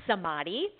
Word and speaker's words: samadhi: so the word in Sanskrit is pronounced samadhi, and it translates as enlightenment.